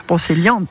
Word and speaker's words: Brocéliande: Brocéliande, pardon, je l'ai mal prononcé, en Bretagne, à Paimpont, en Ille-et-Vilaine.